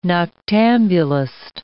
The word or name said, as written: noctambulist